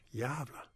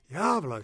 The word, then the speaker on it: Gävle: We're going to Gävle, G-A-V-L-E. It's pronounced Gävle. Gävle.